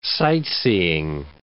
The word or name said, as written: sight-seeing